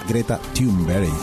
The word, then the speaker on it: Greta Thunberg: ¿cómo se pronuncia en Se pronuncia Greta Thunberg.